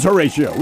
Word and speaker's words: Horatio: It is Horatio, which rhymes with fellatio.